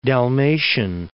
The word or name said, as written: Dalmatian